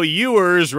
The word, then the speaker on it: Ewers: Somebody says, we know Ewers rhymes with sewers.